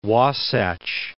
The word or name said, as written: Wasatch Range